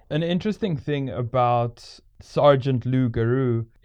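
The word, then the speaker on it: loup-garou: Lou Garou. – Lou Garou. – That's how it's pronounced. – All right. It kind of sounds like Louis Roux.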